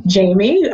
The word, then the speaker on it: Jhaymee: It's great having a name with an unusual spelling, because on Twitter, it's just my first name, Jamie, which is spelled J-H-A-Y-M-E-E.